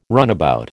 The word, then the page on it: runabout: American English